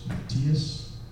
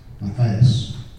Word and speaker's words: Matthias: My name is Matthias. In English people they pronounce it as Matthias.